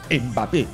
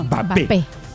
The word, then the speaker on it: Mbappé: Claro, Fernando Carvalho, que es francófono, habla francés, le explicaba a Juan Carlos Ortecho que no se pronuncia Mbappé, es no, Mbappé.